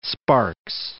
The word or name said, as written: Sparks